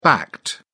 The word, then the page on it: backed: British English